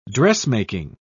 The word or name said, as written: dressmaking